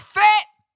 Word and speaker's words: Fett: And, like, it could be that I've just been watching The Mandalorian a lot, but, like, Boba Fett rhymes with jet.